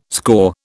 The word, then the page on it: score: British English